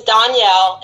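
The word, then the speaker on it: Donyale: But on your Spotify, for those who are listening, my name is Danielle.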